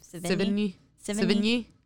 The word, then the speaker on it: Sevigny: Sevigny.